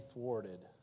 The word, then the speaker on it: thwart: thwarted. What a word. Thwart. Rhymes with wart and court and port.